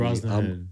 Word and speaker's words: Brosnahan: Rachel Brosnahan, The Marvelous Mrs. Maisel. Hope I pronounced that correctly. – Brosnahan. Rachel Brosnahan. – Yeah. – Thank you.